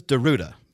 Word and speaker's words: Darruda: My last name is Deruta. D-A-R-R-U-D-A. Not that hard of a thing to pronounce.